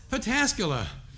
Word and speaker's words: Pataskala: How do you say it? Padulaski? I always forget how you say it. Where am I? Pataskala. I ain't from around here. That was not for show. I butchered that all the time.